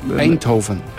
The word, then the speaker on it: Eindhoven: Eindhoven. – Eindhoven, nunca lo voy a decir.